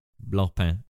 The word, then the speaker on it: Blancpain: You've got this note in here about the the blank pain. How do you say that one? – Blanc pain. – The blank pain. Blancpain.